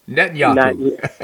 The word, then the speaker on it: Netanyahu: Netanyahu.